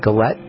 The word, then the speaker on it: galette: You got to check out this picture of the apple spice galette. Is that how you say it? Galette. – Galette. It's really yummy.